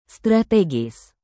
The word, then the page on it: strategis: Indonesian